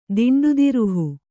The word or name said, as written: ದಿಂಡುದಿರುಹು